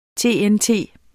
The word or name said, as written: TNT